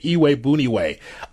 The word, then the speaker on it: Iyiegbuniwe: Iwe Buniwe, IYIEGBUNIWE. Give that name to a loved one and see if they can pronounce it. You'll have fun with that. So I need to know if Iwe Buniwe can be able to be someone next to Roquan Smith on the depth chart that can be able to pay dividends.